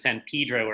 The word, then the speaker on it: San Pedro: It's spelled San Pedro, but the local pronunciation is San Pedro or Pedro.